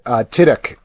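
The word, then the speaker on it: Tudyk: Tiddick.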